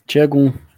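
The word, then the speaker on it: Şengün: Tchegun. Demais, cara. Como é que fala? Tchegun. Eu falo cego. tem, então um som, tem um som de T, C, H.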